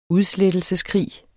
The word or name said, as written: udslettelse